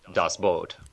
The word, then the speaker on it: Das Boot: And then for something a little bit more fantastical, the 1981 German classic Das Boot. – Ah, Das Boot. – Boot. – Dude, it's spelled B-O-O-T. Therefore, it's Das Boot.